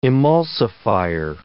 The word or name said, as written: emulsifier